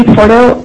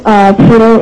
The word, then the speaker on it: Porto: My name is Nancy Porto. Porto is spelled P-O-R-T-O.